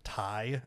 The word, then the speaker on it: Ti: So you're supposed to pronounce it TI as in yeah. Titanium.